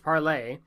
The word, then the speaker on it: parlez: The word parlor or parlez, pronounced parlay, are forms of the French verb to speak.